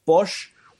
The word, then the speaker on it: Pox: There's a spirit called Bosch, which is P-O-X is how you spell it.